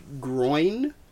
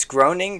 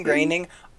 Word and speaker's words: Groening: Sorry, his last name isn't pronounced groin. – It's groaning, graining.